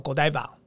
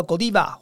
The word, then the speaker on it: Godiva: 我想比利时巧克力里面很有名的就是一个 Godiva 巧克力，那有人把它翻译叫 Godiva。前面说过，比利时它本身呢，因为它有不同的语言嘛，所以你要发音叫 Godiva 或 Godiva 其实都对，那只是法文或德文或是英文上面的一个语音上面的不同。